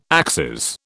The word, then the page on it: axes: British English